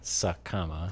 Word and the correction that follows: Sakuma: Sakama, it's Sakuma. I was saying it wrong.